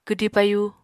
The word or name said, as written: kwdi payu